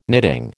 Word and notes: knitting: American English

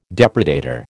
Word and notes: depredator: American English

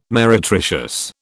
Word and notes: meretricious: British English

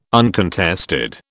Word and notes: uncontested: British English

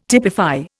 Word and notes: typify: Indian

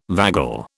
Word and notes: vagal: British English

